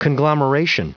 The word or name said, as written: conglomeration